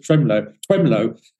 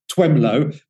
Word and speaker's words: Twemlow: Twemlow. And it sounds like you've got a bit of a speech impediment when you say his name, Twemlow, but it is actually spelled like that, T-W-E-M-L-O-W, Twemlow.